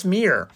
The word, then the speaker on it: Mirror: Perilous Mirror, 2-mana. Oh, and by the way, I've been saying this the whole time, but mirror rhymes with like deer. It's from Myrmidon, so that is how we pronounce it.